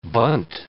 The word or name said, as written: bunt